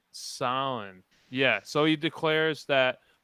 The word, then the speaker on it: Samhain: How do you Sam Hain? Is that how you so? – Pronounce that's it? how it, it would released. would be pronounced phonetically, but it's actually hmm. pronounced Samhain.